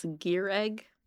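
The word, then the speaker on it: Gireg: It's Gierig. – Gierig. – It's just pronounced Greg. – I wrote G-E-A-R-I-G. – No, it's G-I-R-E-G.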